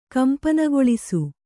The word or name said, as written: ಕಂಪನಗೊಳಿಸು